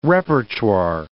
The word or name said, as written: repertoire